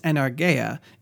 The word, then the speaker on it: energeia: Though it's pronounced energeia, it looks a lot like energia, and it refers to an operative power.